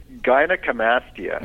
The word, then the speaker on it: gynecomastia: It's funny I'm asking this question because, first of all, how do you pronounce it again? – The medical word is gynecomastia.